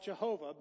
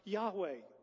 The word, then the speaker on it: Yahweh: We would call it Yahweh. Older days, we call it Jehovah. Most scholars today would agree it's better pronounced Yahweh.